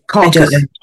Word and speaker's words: Caucasus: The area around Caucasus. How do we say that in English? – Caucasus. The Caucasus Mountains.